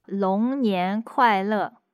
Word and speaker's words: 龙年快乐: 龙年快乐.